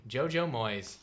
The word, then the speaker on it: JoJo Moyes: Number 4, After You, JoJo Moyes. Did I say that wrong? – You know, you said it just right.